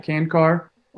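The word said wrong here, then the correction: Čančar: Or how do you say it? – I believe it's Chanchar. – Chanchar, I like him.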